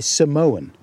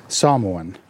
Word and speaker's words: Samoan: I used to say Samoan, but I notice Rose pronounces it differently, so I'm aiming for that. Samoan.